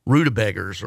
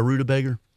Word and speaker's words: rutabaga: You ever had a rutabaga? – I don't think I have. And it's more commonly pronounced rutabaga. – Rutabaga. You know, rutabaga. That's what they say in Kentucky.